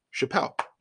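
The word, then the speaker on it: Chappell: Alex Wright, who does some writing for a Mountain West site and some UNLV stuff, I believe, he told me that the correct pronunciation for ex-assistant coach for the Runnin' Rebels is Chappelle, like Dave Chappelle.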